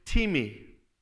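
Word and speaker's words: timi (τιμή): The word that is translated honor in the Bible, it looks like the word time, but it's actually pronounced timi.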